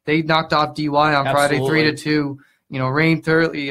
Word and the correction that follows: Turley: Turley, my bad, I mispronounced her last name there.